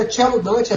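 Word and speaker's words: Cello Dante: Mas o nome dele é Tchelo Dante, é C-E-L-L-O, Dante, D-A-N-T-E,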